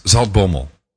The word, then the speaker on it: Zaltbommel: It's Zaltbommel.